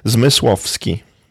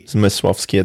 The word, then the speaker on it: Zmysłowski: Zmysłowski. – Misłowski. – Zmysłowski. There's a lot of sh and sh and bzz in Polish language.